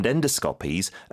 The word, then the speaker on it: endoscopy: 그다음에 이제 미국 발음은 endoscopy라고 하는데 영어 발음 어떻게 된다고요? – Endoscopies. – 주로 앞에 힘을 주네요.